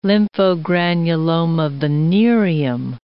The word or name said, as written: lymphogranuloma venereum